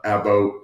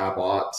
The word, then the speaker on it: Abbott: I get a lot of Abbott or Abbott.